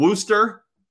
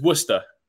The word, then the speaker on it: Worcester: You're not far off. So it's Worcester. – Okay. – I mean, that one gets butchered all the time. It's Worcester, Worcester, Worcester, all sorts, but you weren't far off.